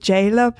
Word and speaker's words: Zayla: But if you ask me who, someone I'm very fond of is, I think she's called Jayla, but it's spelled Zayla, and she is incredible.